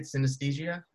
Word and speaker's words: synesthesia: But just for clarification's sake, because I didn't know what it was, how do you say it? Synesthesia? – Yeah, synesthesia.